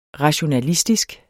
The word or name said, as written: rationalistisk